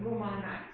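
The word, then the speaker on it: Lou Malnati's: Now, I prefer Little Mama's, which I'm going to have to spell because my daughter says everyone in Illinois pronounces it weird. So it's LOUMALNATIS. But again, in Illinois, you pronounce it Little Mama's. Just run it all together.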